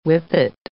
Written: whippet

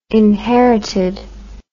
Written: inherited

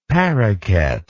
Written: parroket

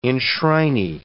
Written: enshrinee

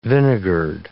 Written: vinegared

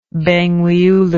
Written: Bangweulu

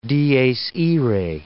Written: Dies Irae

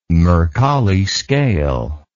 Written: Mercalli scale

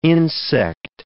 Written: insect

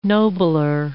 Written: nobler

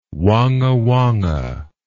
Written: wongawonga

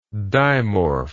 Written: dimorph

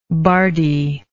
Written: bardy

